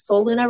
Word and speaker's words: Solluna: So it's spelled SOLLUNA. Soul is the sun. Luna is the moon. So it's, you know, sun, moon, honoring that duality. So Soul Luna Retreats.